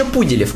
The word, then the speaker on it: Пуделев: Кеша Пуделев, ударение на У. Пуделев.